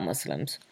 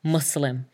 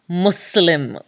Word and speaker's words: Muslim: Also, another aside, but I think a relevant one to mention is the pronunciation of Muslim. It's Muslim with an S, not Muslim, Muslamic, which makes it sound like, I don't know, to me, like you want to say a muslin cloth, and mate, none of us are trying to be that way. And even in Arabic, it's spelt with a seen, not a Z, so it's Muslim.